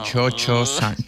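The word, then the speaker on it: Cio-Cio-san: Se escribe Cio. Pero como claro, la pronunciación italiana como Chocho-san.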